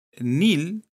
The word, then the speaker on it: Neil: Ya, entonces aquí dice, si uno mira la etimología de estos nombres, te das, te das cuenta que Nil, N-E-I-L, que se pronuncia Nil,